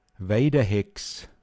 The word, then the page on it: Weichbuschnfrautaog: Pinzgauer Mundart